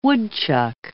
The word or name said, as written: woodchuck